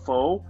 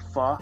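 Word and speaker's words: phở: I buy a pho, or pho as it's pronounced, from a local place.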